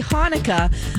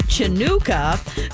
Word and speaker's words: Hanukkah: He requested that Smokey Robinson wish her a happy Hanukkah, the CH spelling of Hanukkah, and he mispronounced it Chanukah.